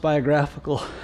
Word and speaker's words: biographical: I mispronounced biographical. I said, we're doing a biological study of David.